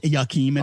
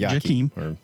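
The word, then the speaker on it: Jakeem: Well, they did introduce Jakim, and it's not pronounced Yakim. It's pronounced Jakim.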